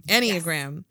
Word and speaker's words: Enneagram: And now we're going to shift over a little bit to Enneagrams. Did I say that right? – Yeah. Enneagram.